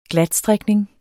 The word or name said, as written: GLATSTRIKNING